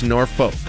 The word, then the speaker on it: Norfolk: Somebody just said I mispronounced Norfolk. – Norfolk. – Norfolk. – Norfolk. – I've been told by people who would know that it's Norfolk.